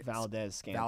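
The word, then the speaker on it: Valdes-Scantling: The the touchdown to Marquez Van, however you say his name, Mark Marquez Vantel Scan Valdez Scantling.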